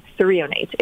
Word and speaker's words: threonate: So magnesium, let me know if I'm pronouncing this right, threonate? – Yeah, magnesium threonate, exactly.